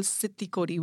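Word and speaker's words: Xitt Kodi: So if you, there's a term called Sitti Kodi, which is, I don't know if I'm pronouncing it right, but it's actually, it's X-I-T-T and Kodi is, you know, K-O-D-I.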